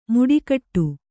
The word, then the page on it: ಮುಡಿಕಟ್ಟು: Kannada